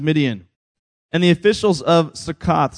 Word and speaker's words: Succoth: So he said to the men of Succoth," got to pronounce that O, although it sounds like a different word, "Succoth,